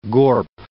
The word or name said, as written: gorp